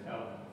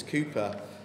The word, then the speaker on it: Cowper: I was reminded of an old hymn by William, is it William Cooper or Cowper? I don't know how you pronounce it. – Cowper. – Is it Cowper? – Cooper. – I think it's Cooper, although it's spelt Cowper.